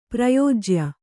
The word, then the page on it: ಪ್ರಯೋಜ್ಯ: prayōjya